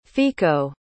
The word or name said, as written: fico